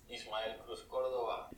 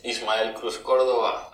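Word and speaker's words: Ismael Cruz Córdova: From Miss Bala, here with the gorgeous Ismael Cruz Cordova. – Did you hear that? Did you hear him say his name? I was nowhere near close. Listen to that again.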